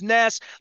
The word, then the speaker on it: Ness: So like, Ness rhymes with Wes, less. It's easier to explain to people. Say my name is Ness, like less with an N, especially with Chinese or where you're getting a Starbucks.